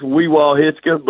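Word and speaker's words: Wewahitchka: It's Weewaw Hitchka, but, you know, most of us can't pronounce it anyway.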